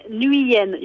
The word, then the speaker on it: Nguyen: No, you said Nguyen. You don't pronounce the G.